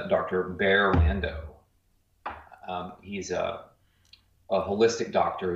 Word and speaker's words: Barre: His name is spelled B-A-R-R-E, Bear Lando, L-A-N-D-O.